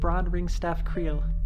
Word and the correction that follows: Greenstaff: And you see it says Bron Greenstaff Creel. – Greenstaff. – Oh, that's what I was missing.